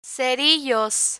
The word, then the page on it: cerillos: spanish